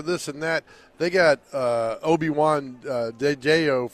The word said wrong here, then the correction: Oladejo: from UCLA. What's his name? Adamato. He's from UCLA. – Oladejo? – Oladejo. I cannot pronounce his name.